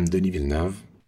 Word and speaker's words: Denis Villeneuve: Hi, I'm Denis Villeneuve.